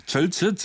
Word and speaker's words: 절체: 사실상 오세훈 시장이 상당히 정체 증명의 위기에 발음이 안 되네. 절체 증명의 절체 발음이 이렇게 어려워요.